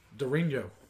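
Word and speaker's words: Durinho: Dorinho. Is that how you pronounce it? – Yes, sir. Yeah.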